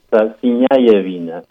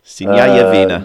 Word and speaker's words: Sinjajevina: It's Sinjajevina. So Sinjajevina. – So the J, they pronounce it like a Y.